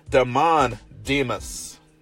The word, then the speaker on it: Damon Demas: Demon Demas. I'm going to try that again. Damon Demas. That's probably it. Yeah, I'm not going to mess that up. Put the wrong emphasis on the wrong syllable. But I got it fixed.